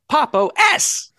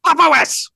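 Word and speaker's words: Pop!_OS: But I guess I should have pronounced the Popo S. Better? – Decidedly not. – Popo S.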